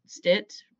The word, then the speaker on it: Stitt: Governor Stitt rhymes with shit.